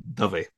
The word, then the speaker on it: Dave: So, nowadays I put an accent on the E just to make sure there is a difference between Dave and Dovey.